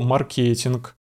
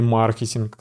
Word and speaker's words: маркетинг: Мы будем говорить про личный бренд, про маркетинг или маркетинг, кстати. И так, и так можно ставить ударение.